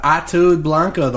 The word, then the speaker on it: Ataúd Blanco: But when they say it, it's just fast. – Yeah. – Ataúd Blanco. – Ataúd Blanco. – Yeah, Ataúd Blanco, the white coffin.